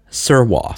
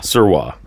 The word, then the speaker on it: Sirois: Am I saying that right? – Fern Sirois. – Sirois, okay. S-I-R... O-I-S. – Yes.